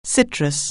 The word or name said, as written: citrus